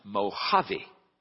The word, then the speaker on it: Mojave: He said, "Sir, it's pronounced Mojave." He said, "Well, it's spelled with a J." He said, "I understand. Here in California, many of our words are Spanish words, and so the J is pronounced like an H."